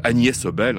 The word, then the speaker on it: Agnes Obel: » On reste en Scandinavie et une musicienne, on va retrouver une musicienne qu'on apprécie particulièrement en France, c'est la danoise Agnes Obel, Agnès Obel, hein, c'est à peu près, j'essaye de prononcer à la danoise son nom, c'est parfait, me dit Stéphane.